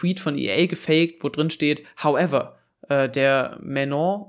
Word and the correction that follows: Maignan: oder wie er heißt, Menon, keine Ahnung, wie man diesen französischen Torwart von Mailand aussprechen sollte. – Maignan. – Maignan, ja, genau. Genau das wird die Aussprache sein.